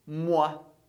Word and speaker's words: moi: Muito simples, a gente diz moi. Moi, que tanto pode ser traduzido como eu ou mim. E a pronúncia é sempre wa.